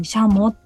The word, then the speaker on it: シャモ: シャモの字はね戦う鳥と書いてシャモと読ませてますので、これね普通一般的には軍ですね、軍隊の軍の鳥と書いてシャモってね、あの読むんですけれども、ここではね戦う鳥と書いて横にシャモと読ませてます。